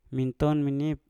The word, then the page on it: minton menip: Mpur